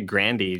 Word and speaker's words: Grande: It's actually Grandy. Did you know that? – No, it isn't. – Mm-hmm. It is. It's Italian. – Ariana Grande.